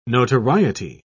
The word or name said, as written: notoriety